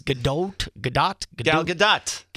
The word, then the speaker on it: Gal Gadot: It's Gal Gadot.